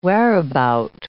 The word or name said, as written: whereabout